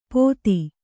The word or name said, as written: ಪೋಥಿ